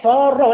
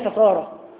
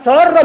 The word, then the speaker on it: سارة: تعلمون الغيرة التي كانت عند سارة، وإذا سميتم بناتكم بهذا الاسم فانطقوا الاسم النطق الصحيح، سارة وليس سارة، سارة يسير سيرا، إنما سارة من السرور، من السرور. وسمعته من خطيب والله يقول يعني زوجة إبراهيم سارة، قلت له سارة العلم في واد وأنت في واد، نسأل الله السلامة والعافية.